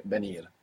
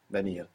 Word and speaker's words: venir: So, of course, you could hear V, like I just pronounced for you, so you won't get the spelling venir, but more commonly you would hear venir, venir, like a soft B.